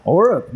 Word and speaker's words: Orup: So next up, how would you pronounce this? – Orup? – Orup. Not bad.